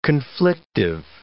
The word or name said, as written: conflictive